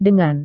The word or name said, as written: dengan